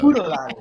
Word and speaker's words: Oodaalolly: I think you have to do it like how I do it. – Oodaalolly, like Eureka. – Oodaalolly.